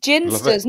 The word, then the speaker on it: Ginsters: A Ginster's pasty. – Brilliant. – Ginsters, not Ginsters. – I used to pronounce it Ginsters, and then someone corrected me and said, no, it's Ginsters.